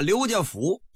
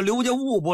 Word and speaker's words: 刘家务: 包括那个服务的务，你要到河北省啊，廊坊西边，有的啊管这个不念务，念什么？念服。啊，刘家福应该叫刘家务不是吗？哎，他叫刘家福，他也不是多音字，说具体怎么来的这个不得而知，反正啊就是这么念。